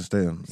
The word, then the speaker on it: Statham: Statham? – Jason Statham.